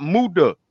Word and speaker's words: Muta: It's not Muta. M-U-T-A. Muta.